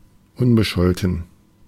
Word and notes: unbescholten: [ˈʊnbəˌʃɔltn̩]

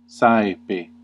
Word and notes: often: American English; [ˈɔːfn]

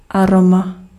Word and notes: arôme: France; [a.ʁom]

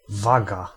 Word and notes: scale: American English; IPA: [skeɪl]

